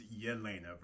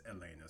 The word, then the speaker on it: Yelena: And I think it's Yelena versus Elena. So my apologies for mispronouncing, or yeah, you know what I'm trying to say. Right. Yelena Dementieva.